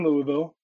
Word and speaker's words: Louisville: Louisville. Do I swallow the second syllable? Okay. Louisville.